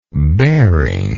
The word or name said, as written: bearing